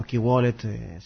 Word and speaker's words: KWallet: Bien, eso es un resumen en breve de lo que puede ser KWallet, esta aplicación, o KeyWallet, si lo pronunciásemos en inglés, tal cual se escribe,